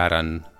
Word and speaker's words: Arran: Bé, es diu Arran, s'escriu Arran, sense accent, però es pronuncia Arran.